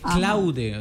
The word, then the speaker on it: Claude: Como que vas a escribir Claudia, sí, pero entonces en vez de I al final le metes E. Claude.